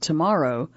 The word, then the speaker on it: TMRW: He quit his job and founded TMRW Sports. Pronounce it tomorrow.